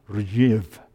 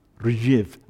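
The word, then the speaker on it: Rzhiv (Ржев): During World War II, the Russian soldiers, and I got Google to say the word for me because, how do you pronounce this word? Rzhiv. Rzhiv. I thought that sounded quite Russian, actually.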